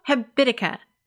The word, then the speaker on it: Habitica: It's called Habitica.